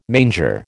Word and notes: manger: American English

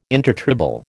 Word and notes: intertribal: American English

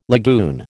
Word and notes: lagoon: American English

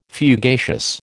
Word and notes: fugacious: American English

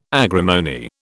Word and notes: agrimony: British English

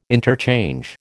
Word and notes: interchange: American English

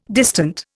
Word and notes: distant: Indian

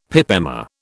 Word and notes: pip emma: British English

